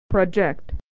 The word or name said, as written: to project